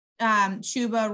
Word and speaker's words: Chuba: So instead of just rolling with Chuba rhymes with Tuba,